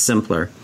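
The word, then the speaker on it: Symplur: It's S-Y-M-P-L-U-R. It's pronounced Simpler.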